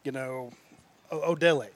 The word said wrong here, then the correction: Oladele: How do you say it? Oladele. – Oladele.